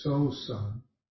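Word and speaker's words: Sōsan: One of the earliest Chinese Zen texts is a text attributed to the third ancestor, Master Seng Tsan, in Japanese pronounced Master So San,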